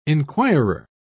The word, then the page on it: enquirer: British English